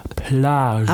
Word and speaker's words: Plage: Ich habe es sehr französisch ausgesprochen. Plage.